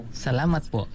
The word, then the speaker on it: salamat po: Salamat po.